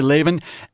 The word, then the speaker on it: Laban: There's the gigantic cheating of Laban, or is that how you say his name? – Yeah. Mm-hmm. – Okay, Laban, against Jacob.